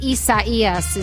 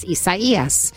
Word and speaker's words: Isaías: Well, I believe Isaías is the correct way to pronounce it. If it is a Spanish word coming from the Spanish pronunciation, it would be pronounced Isaías.